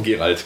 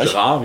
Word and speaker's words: Geralt: Geralt, genau, ja. Oder Geralt oder ja, Gerard, wie man es auch aussprechen möchte.